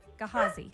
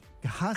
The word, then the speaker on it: Gehazi: Gehazi. – Gehazi.